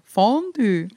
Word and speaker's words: Fondue: Der Betonung vorne. Fondue.